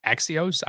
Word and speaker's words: Axios: But I got this from an article put out by Axios.